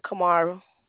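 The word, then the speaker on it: Kimara: Kamara.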